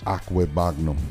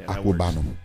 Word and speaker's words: Okwuegbunam: Albert Okwuegbunam. Hopefully I said that right. Tight end for the Missouri Tigers. – Okwuegbunam. – Yeah, Okwuegbunam.